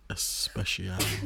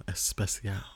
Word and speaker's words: especial: It was a bad pronunciation. It was very Americanized. – Especial. – Especial. – How you say it? – Especial. – Oh, especial.